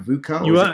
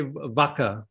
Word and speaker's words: VUCA: Tell us about VUCA, V-U-C. Am I saying it right, VUCA? – I mean, some people say VUCA and some people say VACA, but anyway, whichever way you say it, right?